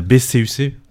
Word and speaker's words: BCUC: Et puis en plus, après, pour enchaîner, il y a BCUC, c'est comme ça qu'on prononce, c'est un groupe qu'on a découvert du côté du transmusical, une grosse claque, j'y étais.